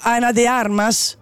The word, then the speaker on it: Ana de Armas: Ana de Arm, ah, scusatemi, lo pronuncio sicuro sbagliato. Ana de Armas, de Armas è fuori scala, scrive Alex.